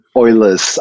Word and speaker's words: Euler: with which is the Euler identity. Is that the correct pronunciation? – Yeah, Euler's identity, as in the identity belonging to Euler.